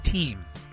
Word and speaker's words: Thiem: No, his last name is pronounced Team.